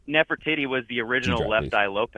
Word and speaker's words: Nefertiti: And honestly, on the plates that they have, they'll say, like, this, there was one that was a statue of Nefertiti. Is that how you say it? – Oh, yeah.